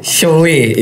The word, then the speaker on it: Shoei: Kalau Shoei, malah yang gue inget Oh bacanya Shoei ya? – Shoei. Iya. Shoei.